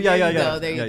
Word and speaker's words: Iñárritu: Iñárritu.